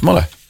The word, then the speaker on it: Mullagh: Is it like a silent E-T? – M-U-L-L-A-G-H. Muller. Muller County, Cavan.